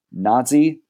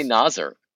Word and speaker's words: Nazr: NASR, but pronounced Nazi, correct? – NAZR, I believe. Nazi NAZR. Mohammed. Yeah. – Right. Tough. I mean, like, it's just tricky. Yeah. You know what I mean? – Yeah. And it ends in an R, so like, don't lean into the fact that it's kind of Nazi. – That's it. That's the problem. It's but no one pronounces it Nasser, correct? It's E, Nazi. – Yeah. Just say Nazer.